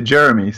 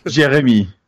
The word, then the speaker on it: Jeremy: Well, you can pronounce it as an American, so you could say Jeremy, so that's okay. Don't worry. – Gotcha. Okay. What's the correct French pronunciation, though? – Jeremy.